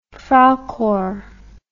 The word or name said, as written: freikorps